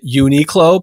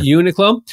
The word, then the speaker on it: Uniqlo: Some people pronounce it Uniqlo, but I pronounce it Uniqlo.